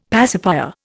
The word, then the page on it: pacifier: Indian